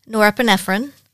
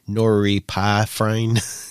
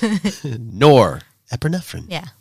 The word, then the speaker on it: norepinephrine: We have serotonin, oxytocin, norepinephrine, vasopressin, and nitric oxide. – I have trouble with the norepinephrine. I want to call it like noripifrine. You know, Kentucky. – Right. It's not. – It's norepinephrine.